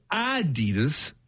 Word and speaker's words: Adidas: We pronounce Adidas wrong. It's supposed to be Adidas. Uh, slight emphasis on the first syllable, not an Adidas as we stress the second syllable.